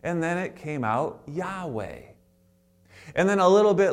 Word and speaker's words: Yahweh: And instead of the Y being Yah, it became Jah, and now we have Jehovah. So Yahweh, Jehovah are the same names, just different syllables, inserting different vowels.